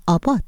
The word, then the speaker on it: ABBA: بشنوید که هواداران گروه سوئدی افسانه موسیقی پاپ ابا که در ایران آبا تلفظ میشه، 50مین سالگرد برنده شدن این گروه رو در یوروویژن 1974 میلادی جشن میگیره.